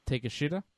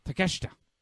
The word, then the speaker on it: Takeshita: I found out if you say his name like in English, it comes out a bit differently, but yes. – Takeshita? – There you go. – Takeshita.